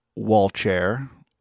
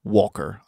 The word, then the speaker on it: Walcher: Uh, that's spelled with a CH and not a K, so it could also be pronounced Walcher or Volker or Walcher, depending on language and dialect, uh, but I'm going to go with the simpler Walker option.